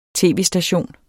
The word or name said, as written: tv-station